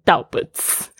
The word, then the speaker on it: doubts: And your doubts，注意一下这个 doubts，我不要再听到的同学念 doubts 哦。这个 bt 呢，结尾的通常都是不发 b，像另外一个字 d e b t，debt 不要念成 debts。 OK，这个是一个潜规则，一定要注意它发音的这个组合。 bt 的 b 就是不发音，doubts。啊，就是只有发 ts 这个声音。